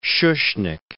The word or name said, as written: Schuschnigg